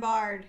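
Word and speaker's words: Byard: So, Kevin Bayard. – Yep. – Did I say his name? Kevin Bard.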